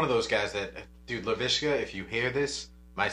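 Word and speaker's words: Laviska: So, I was one of those people that took LaVisca. Is it LaVisca or LaVishka? – LaVisca. – LaVisca, okay. Yeah.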